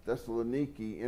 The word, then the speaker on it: Thessaloniki: Rhonda and I were talking about these things, and somebody was mentioning the city of Thessaloniki in Turkey, and that's the Greek pronunciation, Thessaloniki, N-I-K-I at the end, of what we call in English Thessalonica.